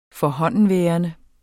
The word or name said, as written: forhåndenværende